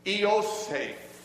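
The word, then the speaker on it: Eosaph (Ἰωσήφ): If you were to say his name, the, the English pronunciation of the Greek is Eosaph.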